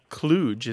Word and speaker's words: kludge: And then the other word, just to dispense with that pretty quick, kludge is how it's often said. The kludge is how it looks like it's spelled, K-L-U-D-G-E,